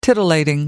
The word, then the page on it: titillating: American English